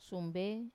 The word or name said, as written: sumbe